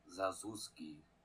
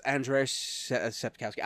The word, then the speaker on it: Sapkowski: I think it was Andrzej Sapkowski? Sapkowski? – Zazuski. – Yes, oh no, it's Andrzej Sapkowski.